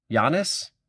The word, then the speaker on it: Giannis: I would have done Y-O-N-N-I-S. – Interesting. Okay. – Yeah, because I think you hold the yan enough. Like, it's Giannis. It's the two parts of the syllables, like Giannis.